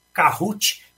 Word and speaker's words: Kahoot: Ele se escreve como Kahoot, é K-A-H-O-O-T, Kahoot. A gente fala Kahoot, né?